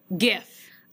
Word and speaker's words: GIF: do you pronounce it with a hard G or a soft G, and I will tell you that 63.5% of the people pronounce it correctly with the hard G GIF.